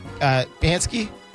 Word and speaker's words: Psaki: according to Press Secretary Jen Psaki. – Psaki. – Whatever. – Psaki. – Psaki. All right. – Hey, the P is silent.